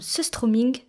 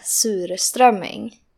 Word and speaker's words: surströmming: Mais avant de vous raconter son histoire, je suis partie à la rencontre d'une Suédoise pour savoir exactement comment le terme surströmming se prononce. uttalas som eh, surströmming. Surströmming. Surströmming donc. C'est un mot composé de sur, aigre, et strömming, hareng.